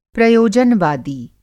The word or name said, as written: प्रयोजनवादी